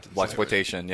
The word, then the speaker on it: Blaxploitation: Blaxploitation, yeah.